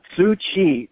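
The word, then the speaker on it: Tzu Chi: Yeah, it's Tzu-Chi, that's spelled T-Z-U, is one word, and the other word is Chi, C-H-I, Tzu-Chi. – Okay. – And the woman who founded it, her name is Heng Yen, H-E-N-G, and then second word is Y-E-N, Bhikshuni or Master Heng Yen.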